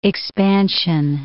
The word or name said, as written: expansion